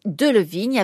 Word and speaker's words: Delevingne: Et donc, Delevigne avec cette prononciation française.